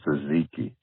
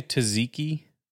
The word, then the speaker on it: tzatziki: Euro not gyro, like a gyroscope, gyro, and tzatziki. The T is silent. Delicious. – Did you say tzatziki? The T is silent? Oh, the other T? Tzatziki? Tzat, tzatziki.